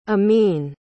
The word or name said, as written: ameen